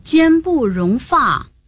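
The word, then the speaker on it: 间不容发: 哦，只要是指向毛发都读发，这样我就明白了，得读作奸不容发。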